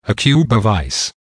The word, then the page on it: a cube of ice: American English